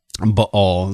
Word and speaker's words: Baal: But also during the time that Baal was dead, apologize for me flipping back and forth between my pronunciation. Baal is the way you would say it in Hebrew.